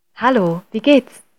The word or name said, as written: hallo wie gehts